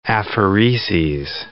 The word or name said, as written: aphereses